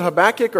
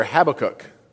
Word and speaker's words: Habakkuk: Now, did he pronounce it Habbakuk or Habakkuk? Perfect. So I'm going to be good either way.